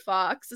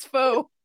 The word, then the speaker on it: faux: Like, no, that's not fox, it's faux.